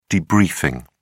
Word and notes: debriefing: British English

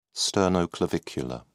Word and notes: sternoclavicular: British English

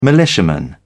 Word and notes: militiaman: British English

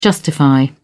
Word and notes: justify: British English

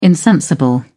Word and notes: insensible: British English

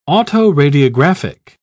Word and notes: autoradiographic: British English

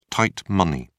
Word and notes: tight money: British English

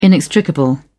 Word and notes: inextricable: British English

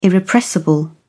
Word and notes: irrepressible: British English

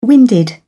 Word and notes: winded: British English

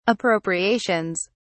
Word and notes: appropriations: British English

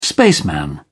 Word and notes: spaceman: British English

